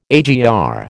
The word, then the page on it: AGR: American English